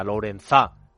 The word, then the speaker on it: Lourenzá: Lourenzá, con acento en la A,